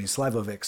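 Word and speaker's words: šljivovica: It's called Slivovic is the pronunciation. The spelling has an L, a J, a couple of V's. I mean, it doesn't quite work out if you were to just try to pronounce it phonetically.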